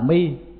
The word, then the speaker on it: mi: Sau đó ngày tìm tòi thấy rằng ở Trung Quốc, người Trung Hoa người ta đọc là mi, mà theo âm Hán Việt đó là di, nhưng mà âm Bắc Kinh hay là Phạn á thì là mi chứ không phải là di.